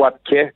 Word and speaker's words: Wapke: Écoute, Wapke, est-ce que je le prononce bien premièrement? – En fait, on prononce e Wapke comme un accent grave.